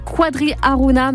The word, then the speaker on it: Quadri Aruna: Et j'ai mal prononcé, pardon, le prénom du joueur, c'est Quadri Aruna, mais on l'entendra effectivement dans notre chronique Picardie Sport.